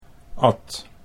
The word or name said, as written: Naam